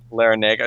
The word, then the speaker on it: Larrañaga: Larrañaga. It's just Larrañaga. Okay, that's the boring pronunciation.